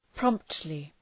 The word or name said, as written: promptly